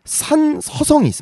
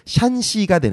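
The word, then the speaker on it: 샨시가 (山西): 그다음에 산서성이 있습니다. 산서성. 네, 중국어로는 이제 샨시가 되는데 샨시성이 있고 그다음에 내몽골 자치구.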